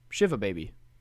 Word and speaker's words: Shiva Baby: What is the correct way to pronounce that movie? – Shiva Baby. – Like, for sure, 100%? – Yeah, Shiva Baby.